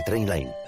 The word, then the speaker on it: Trainline: Se pronuncia Trainline. Descarga la app de Trainline y ahorra comparando miles de trenes y tarifas.